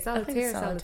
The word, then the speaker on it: solitaire: and I've been sitting up here playing solitary on my phone. – Solitary. – How do you say it? Solitaire.